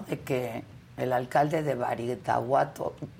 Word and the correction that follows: Badiraguato: Badiraguato, lo dije bien, ¿no? – Sí, Badiraguato.